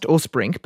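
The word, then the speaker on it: Åsbrink: And Elizabeth's name is spelt A-S-B-R-I-N-K, by the way. It's pronounced Orsbrink, but if you're looking for the book, it's a real page-turner.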